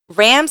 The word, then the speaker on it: Ram: Okay, so we have Ram Sanchez, pronounced just like pickup truck animal or computer memory.